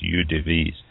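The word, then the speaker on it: eaux-de-vie: First, it can be made of up to 40 different UDVs, and that's EAUX-DE-VIEX, and I believe that's pronounced UDVs, that come from all of the six crews.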